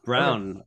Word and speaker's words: Braun: I wanted to actually talk a little bit about Christian Brown, pronounced Brown, apparently not Braun. I learned that for the first time this week.